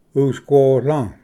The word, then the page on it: ᎤᏍᏉᎳ: usquola